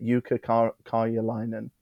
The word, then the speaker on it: Jukka Karjalainen: You butchered that. – Jukka Karjalainen. I think that sounds Finnish to me. – Karjalainen. – I've given it a right go. Karjalainen. That's probably right, isn't it? Jukka Karjalainen. – Yeah. – Good job.